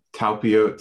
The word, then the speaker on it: Talpiot: And you talk about the relationship between Mary Magdalene, and you mentioned the, now correct me if I'm wrong how to pronounce this, the Talpiot tomb? – Talpiot tomb, yes. Okay, I knew I was saying that wrong.